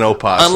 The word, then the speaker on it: opossum: The O is silent in opossum. – Unless, of course, you're Irish. – Or Irish possums.